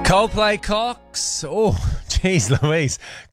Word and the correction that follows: Clocks: Clocks. Really got to pronounce the L in that one. It really caught me slipping.